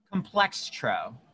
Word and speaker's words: Complextro: Complex. How do you say it again? – Complextro. So it's complex and then T-R-O at the end.